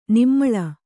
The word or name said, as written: ನಿಮ್ಮಳ